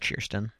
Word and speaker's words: Kirsten: Well, my birth name is actually pronounced Kirsten. – Kirsten. – Yeah, the K makes a CH noise.